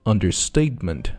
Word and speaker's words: understatement: 注意这个词呢有两个重音，首先是它的次重音 under, 啊，比平调稍微高一点 statement, 这是重音位置 statement, date 这个地方是一个重音 understatement, 所以说读起来呢就是Understatement.